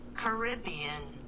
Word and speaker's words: Caribbean: Go on, like, Google Translate and see how they pronounce it. How to pronounce Caribbean. All right. – Caribbean. – So we have it in slow-mo now? – Caribbean. So the movie weighs the wrong way.